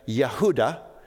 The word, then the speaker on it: Yehuda: The Hebrew pronunciation would have been Yehuda, and this was the name of the individual writing this letter.